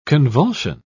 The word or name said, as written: convulsion